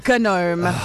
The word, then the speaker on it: gnome: This word gnome has this weird silent G, a gnome. G-N-O-M-E.